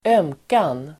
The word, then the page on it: ömkan: [²'öm:kan]